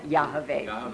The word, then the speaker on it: Yahweh: Okay, Yahweh.